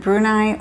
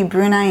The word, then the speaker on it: Brunei: And I don't know if it said Brunei or Brunei. I've looked it up. It seems to be Brunei in English, but the local people here do say Brunei, and that's what it's spelled like.